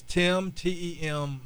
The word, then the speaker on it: Tem: So it's Tem, T-E-M, Miles, M-I-L-E-S.